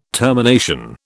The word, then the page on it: termination: British English